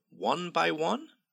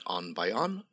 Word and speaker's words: one by one: I'll go down through them one by one. Not on by on. One by one. Some people confuse the pronunciation there.